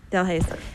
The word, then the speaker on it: Delhaize: I don't know how to say the other one. Uh, Delhaize? Yes, Delhaize.